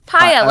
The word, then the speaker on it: Paya: It's Paya, like papaya.